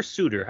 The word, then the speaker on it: Suter: Suter is it Suter or Suter? How do you pronounce it? Suter? – Suter, because he's Swiss. Suter. He's not one of the Sutters, one of the 20 yeah.